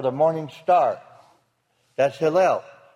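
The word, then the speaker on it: Hillel (הילל): It has nothing to do with Hallelujah, Yahweh, or Halal, or anything else. It's Hillel. It is spelled, as you'll notice, Hey, Yod, Lamed, Lamed. It has Hillel in it. That's Hillel, totally different.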